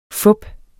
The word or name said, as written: fup